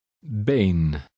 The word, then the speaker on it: been: The Dutch double E sounds similar to the long A, as in the word make. Try to read number 13. – Been.